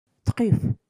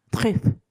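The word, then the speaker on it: truffe: えー、そしてですね、食材の名前になりますが、トリュフ、この香りのいい食材、トリュフですが、これもゅうのところがエアですので、フランス語で発音すると、トリュフ、トリュフになります。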